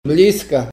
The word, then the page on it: bliska: Ninilchik Russian